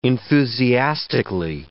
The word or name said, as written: enthusiastically